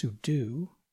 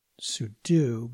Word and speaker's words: sudo: So to get started, you'll hear the term SU or SU or SUDO or sudo, sometimes pronounced sudo, I think more accurately pronounced sudo, but more commonly pronounced sudo.